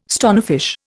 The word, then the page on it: stonefish: Indian